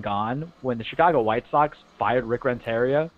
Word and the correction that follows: Renteria: I don't know why I said Renteria. I think I was reading it phonetically, and I forgot that I have some Hispanic roots in me. Renteria. – Roll those R's, man.